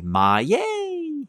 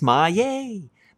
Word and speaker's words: Maillet: How about this one? M-Y-Y-A-Y. Maillet! It's mine. It's Maillet. No, that's not how it's spelled, but that's how it's pronounced. Stéphane Maillet. How is it spelled? Oh, M-A-I-L-L-E-T as in Thomas.